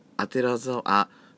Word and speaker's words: 左沢: あのあてらざあ左沢線と書いてあてら沢線と読みます